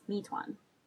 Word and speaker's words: Mi-Twan: Mi-Fwan? Mi-Twan? It sounds like the name of a restaurant. How do you say it anyway? That's what my Uber driver just told me. Actually, it's just my name, and it's Mi-Twan.